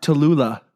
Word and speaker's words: Tallulah: Tallulah.